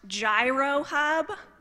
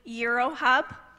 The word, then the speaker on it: Gyro Hub: It looks like it's pronounced Gyro Hub, but it's actually Euro Hub.